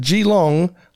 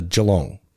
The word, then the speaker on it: Geelong: He goes the extra mile, and he had done his research, and he didn't pronounce it Geelong like so many American visitors do. He got it down to Geelong.